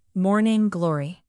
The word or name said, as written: morning glory